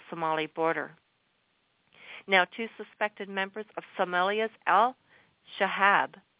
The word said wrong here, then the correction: Al-Shabaab: or actually, if you want the proper pronunciation, I can arrange that for you. Al-Shabaab.